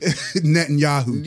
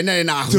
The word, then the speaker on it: Netanyahu: Netanyahu. – Yeah, Netanyahu.